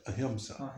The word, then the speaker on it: ahimsa: Just don't let people around you get away with pronouncing it ahimsa. – Ahimsa, yeah. – Ahimsa. – Ahimsa. – And that dot over the M means you say it through your nose. Ahimsa.